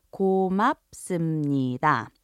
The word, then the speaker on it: 고맙습니다: Once again, it's pronounced 고맙습니다.